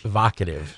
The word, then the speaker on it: evocative: This glorious, a big word I can't pronounce, journey will captivate audiences. You're smarter than me, okay? Evocative. – Evocative. – It's evocative.